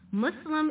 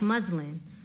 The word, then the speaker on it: Muslim: No, Muslim is not pronounced like Muslin. Muslin is a piece of fabric.